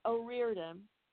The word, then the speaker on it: O'Riordan: You know, Eddie Munoz from the Plimsouls, Corch O'Rourke or from the I always mispronounce her name, but she's from the Pogues. – Corch O'Riordan from the Pogues, and uh, yeah, so we just had a really great time, all all around.